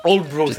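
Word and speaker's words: Aldeburgh: Et je crois que vous m'avez dit que vous êtes allé dans le village qui a oui, ça s'appelle Oldbro, ça s'écrit Aldebourg, mais ça se prononce Oldbro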